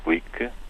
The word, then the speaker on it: Glück: Luísa Glick, eh, pronuncia-se assim porque o apelido é de origem húngara, ela é uma imigrante, os pais foram imigrantes húngaros nos Estados Unidos,